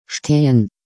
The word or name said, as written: stehen